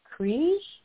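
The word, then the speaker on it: Krige: And I met for the very first time Alice Krij.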